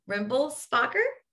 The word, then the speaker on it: Rimmelspacher: Today I have on Wendy Rimblespacher.